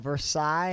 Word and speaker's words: Versailles: And it's The Queen of Versailles. Have you seen this? – No. – It's about... – I'm so proud of you for pronouncing Versailles right.